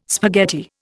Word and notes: spaghetti: Indian

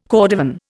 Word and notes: cordovan: Indian

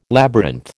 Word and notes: labyrinth: American English

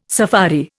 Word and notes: safari: Indian

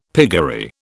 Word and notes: piggery: British English

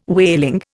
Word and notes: whaling: Indian

